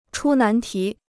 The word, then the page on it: 出难题: chū nán tí